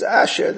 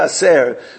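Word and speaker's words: aser (עשר): So aser, give your tzedakah wisely, b'shvil shetis asher, that you should become wealthy. Rabbeinu Bachi actually says that we're mispronouncing it. It's aser, give ma'isim, b'shvil shetis aser, so you should give more ma'isim.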